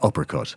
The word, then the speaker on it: Hypercut: A lot of people have existing punch bags that they use to train at home, but a French company have called Hypercut, which essentially is pronounced uppercut.